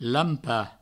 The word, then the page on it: lampar: lampa; Provençal